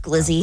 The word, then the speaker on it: Glizzy: It's pronounced Glizzy, okay. By the way.